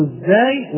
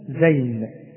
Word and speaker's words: الزاي: والطاء وإن طائفتين بين كلمتين، وإن طائفتين، وإن طائفتين، وفي كلمة واحدة وينطقون، وينطقون أو ينطقون، وفي التنوين قوما طاغين، وفي الزاي ونقول الزاي ولا نقول زين، اسمها الزاي ولا نقول زين كما يقول البعض، ولكن اسمها الزاي، وهي مثل الراء، وتكون ونقول معجمة.